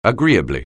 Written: agreeably

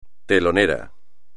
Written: telonero

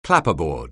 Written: clapperboard

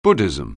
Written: Buddhism

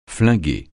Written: flinguer

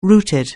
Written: rooted

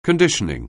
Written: conditioning

